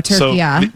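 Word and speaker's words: Türkiye: So I will be saying it turkey-a from now on. – From now on. Yeah, and my entire lifetime, I have been, and we have been, incorrectly pronouncing turkey.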